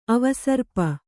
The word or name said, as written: ಅವಸರ್ಪ